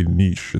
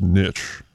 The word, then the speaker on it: niche: And he was like, why did you say niche? It's niche.